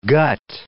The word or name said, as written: gut